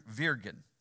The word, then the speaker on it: wyrgan: But uh, but this is an old English word that I'm talking about called vir- virgin. Virgin. It's pronounced. It's it's spelled W Y R G A N. So why it sounds like virgin, I don't know, but it's old English, and that word.